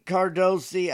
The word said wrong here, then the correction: Cardoso: I think, is a player that Cardoso. I'm sorry, I'm pronouncing it wrong.